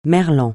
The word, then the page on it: merlan: francais